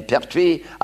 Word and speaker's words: Pertwee: It says, due to the inability to pronounce the name Pertwee, other than Pertwis, it was changed to Pertwee.